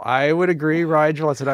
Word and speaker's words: Rigel: So for example, brightest star in the constellation Orion, R-I-G-E-L.